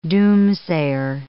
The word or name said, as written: doomsayer